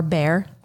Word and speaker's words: Bexar: Bexar County is actually spelled B-E-X-A-R County. – Gross. – Right. It looks like Bexar, And everyone pronounces it bear. And if you don't say that, they think you're strange.